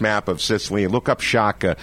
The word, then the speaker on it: Sciacca: They came over in a boat from Sciacca.